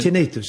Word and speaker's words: tinnitus: Tinnitus uttalar det annorlunda. – Ursäkta en gång till. – Tinnitus, ja. Du säger... – Tinnitus ska det uttalas.